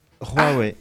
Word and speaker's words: Huawei: d'où sa distribution aujourd'hui unique avec pour titre Huawei, la guerre froide entre États-Unis et Chine. – Pardon ? – Huawei.